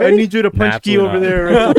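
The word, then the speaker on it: pączki: We're gonna figure out how to say it. – Punchkey. A punchkey. – Punchkey. You were close.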